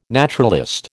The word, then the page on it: naturalist: American English